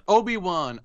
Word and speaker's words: Obi-Wan: Obi-Wan, I hope I'm pronouncing that right.